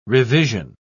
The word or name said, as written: revision